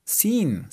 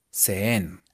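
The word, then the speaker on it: seen: Sin, ¿sí S-E-E-N. E e Entonces recuerden, escrito sonaría así. Se, sau, sen. Ok. Pero pronunciado de manera correcta, de manera gomelita. Si, so, sin. Sí, so, sin.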